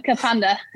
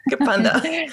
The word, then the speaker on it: Kapunda: And forgive my pronunciation if this isn't right, but you're from Kapunda in South Australia? – Yeah, right? Nearly got it. Kapunda. – Kapunda.